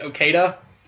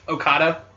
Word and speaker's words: Okada: I would like to see, I had to, like, in my head, I have to say it right, because for some reason I've always pronounced it Okada, but it's Okada.